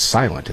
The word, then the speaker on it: SLNT: SLNT. pronounce it as silent, and that makes sense, doesn't it?